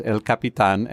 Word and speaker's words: El Capitan: I do speak Castilian, I have a tendency to pronounce it as El Capitan.